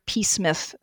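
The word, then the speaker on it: Psmith: Couldn't find out the title he had in mind, but it's very probably one of the Smith novels, because they're P. Smith. But there's a big joke in Wodehouse about the P is silent, like in Ptarmigan.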